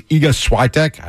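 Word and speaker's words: Iga Świątek: American Jessica Pegula plays Iga Swiatek. I don't know if I'm saying that right. – Yeah, you're saying it right.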